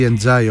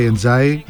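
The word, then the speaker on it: Ndiaye: είναι πλοκάμια του Ενδιαγέ ή Εντζάι, όπως το προφέρει ο ίδιος, έτσι λίγο αμερικάνικα. Και έχει πολύ ενδιαφέρον αυτή η περίπτωση του Εμπάι Εντζάι, όπως το προφέρει αυτός.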